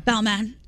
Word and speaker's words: Balmain: Balmain.